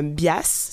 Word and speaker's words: Bias: Donc c'est présenté par euh, la fondation euh, Bias, si je le dis bien, donc B-I-A-S.